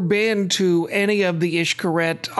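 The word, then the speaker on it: Xcaret: So we have been to the Cancun area many times before the hotel was built, and we've seen the Ixcaret parks, which looks to our eyes as saying Ixcaret, but it's pronounced Ixcaret.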